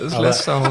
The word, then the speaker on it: Leicester: Ich dachte gerade, was ist denn ein Leicester Hospital, wo die ganze Zeit am Leicestern sind, oder? – Ja, gegen Autofahrer vor allem. – Das ist Leicester, Leicester Hospital.